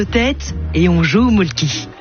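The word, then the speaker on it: Mölkky: Inventé en Finlande en 1996, ce jeu de quilles s'écrit M-O-T-R-E-M-A-L-K-K-Y. – Ça s'écrit molki, mais ça se prononce molke. – Le molke. – Plutôt un E qu'un E, mais pas un U. Même ça fait molke.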